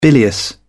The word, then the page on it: bilious: British English